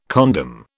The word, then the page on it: condom: British English